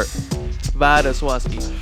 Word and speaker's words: Wladyslawski: Vaduswaski.